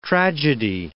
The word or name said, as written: tragedy